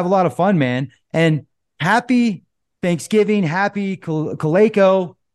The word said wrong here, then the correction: Shalako: Did I say that right? – Shalako. Shalako. – Shalako. Happy Shalako.